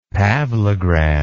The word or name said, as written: pavlograd